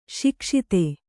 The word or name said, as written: ಸೊಗಸಿತು